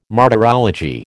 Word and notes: martyrology: American English

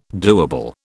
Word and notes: doable: British English

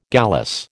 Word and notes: gallice: American English

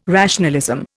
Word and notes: rationalism: Indian

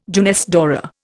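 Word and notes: jeunesse dorée: Indian

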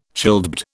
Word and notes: childbed: British English

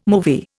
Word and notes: movie: Indian

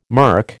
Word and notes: mirk: American English

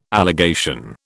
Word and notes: allegation: British English

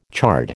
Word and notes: chard: American English

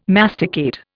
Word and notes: masticate: Indian

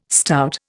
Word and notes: stout: Indian

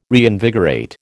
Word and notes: reinvigorate: American English

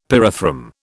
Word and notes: pyrethrum: British English

